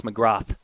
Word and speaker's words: McGrath: He does like it, by the way, to be pronounced McGrath, as in TH. Correct. At the very end.